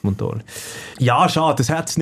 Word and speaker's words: Montolio: Gott, Betonung auf dem O. – Kann man das nicht? Gesagt? Montolio.